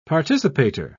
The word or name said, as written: participator